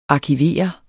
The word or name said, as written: arkivere